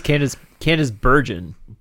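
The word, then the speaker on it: Candice Bergen: am I saying her name wrong? – No, I think it's Candace Bergen.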